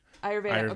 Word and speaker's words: Ayurveda: Ayurveda.